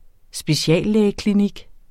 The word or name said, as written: speciallægeklinik